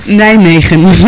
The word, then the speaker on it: Nijmegen: On the other hand, near the German border, you have two cities, one called Arnhem and the other is Nijmegen. I won't do the hard G, like, I won't shock you. – Nijmegen. – Nijmegen, yeah, right.